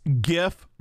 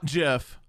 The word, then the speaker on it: GIF: It's GIF, not JIF.